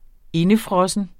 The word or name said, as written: indefrossen